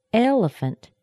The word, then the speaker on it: elephant: Elephant. What has the strongest sound here? Elephant. It's the first E. It has the strongest, longest, highest pitch of the three syllables in this word. That's the primary stress.